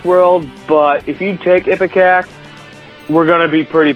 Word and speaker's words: Ipecac: You correctly pronounced and gave the correct definition for Ipecac. – Ipecac, yeah.